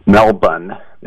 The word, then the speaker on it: Melbourne: I remember a friend of mine was going to Melbourne. They say don't pronounce the R.